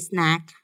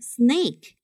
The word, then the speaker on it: snack: With a tasty snack ready，准备好吃的点心。 Snack 点心零食，注意不要读成 snake，是蛇的意思。